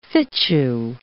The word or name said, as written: fitchew